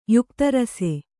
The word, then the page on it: ಯುಕ್ತರಸೆ: yukta rase